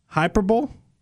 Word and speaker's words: hyperbole: It's a form of aggression, and it doesn't rhyme with a bowl. Okay. Hyperbole?